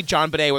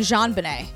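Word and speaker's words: JonBenét: I cannot make myself say John Bonet with a hard J. – Because it's been decades of everyone saying Jean Bonet. – I know, because that's the way it should be said.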